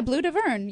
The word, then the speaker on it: Bleu d'Auvergne: Now, I'm not pronouncing this right. Bleu d'Auvergne, is it? – Yeah, Bleu d'Auvergne. Yes, it's a blue. It's a French blue from Auvergne.